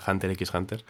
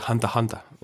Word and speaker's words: Hunter x Hunter: La X es muda, ¿no? Como nuestra H. – Sí, en este caso, eh, después de años pronunciando mal Hunter Hunter, yo decía Hunter X Hunter, descubrí que se dice Hunter Hunter.